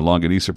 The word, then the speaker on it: longanisa: Homemade Filipino longanisa pronounced longanisa.